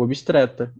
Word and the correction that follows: obstetra: Obst... – Obstretra. – Obstetra. Obstetra. Pronunciei errado, galera.